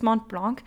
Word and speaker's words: Mont Blanc: Mont Blanc. I think I said it wrong the first time. I can't remember, but it's Mont Blanc.